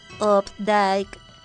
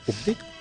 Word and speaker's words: Updike: En 1932 también nace el escritor estadounidense John Updike o Updike. – Y en el año 1933, el escritor mexicano Sergio Pitol. La computadora, cuando tú le dices pronúncialo, te dice Updike. – Perfecto, Updike u Updike.